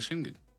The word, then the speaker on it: Schengen: Es esto, el espacio de Schengen.